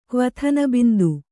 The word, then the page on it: ಕ್ವಥನಬಿಂದು: Kannada